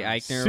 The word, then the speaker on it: Eichner: Um, and then I didn't even know Billy Billy Eichner. Is that how you say his name? – yeah, Billy Eichner.